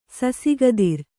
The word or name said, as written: ಸಸಿಗದಿರ್